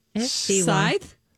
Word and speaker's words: scythe: and how do we pronounce this word? S-C-Y-T-H-E. – Scythe? – Scythe.